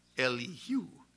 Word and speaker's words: Elihu: Then the wrath of Elihu, or actually in the Hebrew, it is the accent is on the who. It's Elihu.